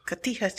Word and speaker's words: Cotija: It was pork belly burn-ins, a little cajita cheese. Did I say that right? – Cotija cheese.